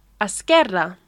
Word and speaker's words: esquerra: Left is esquerra. – Esquerra. – It's that double R. Can you roll your R's? – No, I can't.